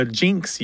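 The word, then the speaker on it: jynx: And it was spelled in Latin I-Y-N-X, but that I is often changed to a J because there's no J in Latin. And so I before Y can sound like a J. In modern Latin, it is spelled with a J, J-Y-N-X.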